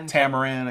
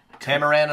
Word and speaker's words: Tamaran: Was it Tamara? I can't say it right. – Sexatonia. – Tamaran. – I can't say it right. – That's a drink, I think. – Tamaran on the beach, I think it's called.